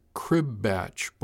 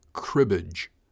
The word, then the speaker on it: cribbage: He called it a crib batch board." His brow furrowed. "Crib something." "Cribbage, Mr. Hill. Cribbage." I pronounced it distinctly for him.